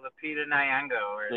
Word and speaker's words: Lupita Nyong'o: Lupita Nyong'o. I don't want to butcher her name right now. – So I think you had it right, Lupita Nyong'o.